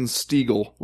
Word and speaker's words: Stiegel: Here's one from Jason Stiegel, rhymes with eagle.